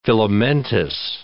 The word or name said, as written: filamentous